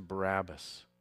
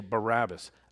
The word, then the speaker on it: Barabbas: And I often wonder, what happened to Barabbas? Incidentally, in preparing for this message, his name actually means, we say it wrong, we say Barabbas. Actually, it's Bar-Abbas.